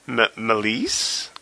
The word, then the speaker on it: Meleys: And we also learned that the name of Rhaenys's dragon is Mellisse, I believe is how she pronounced it. – That sounds about right. – M-E-L-E-Y-S.